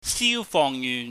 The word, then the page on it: siufohngyuhn: cantonese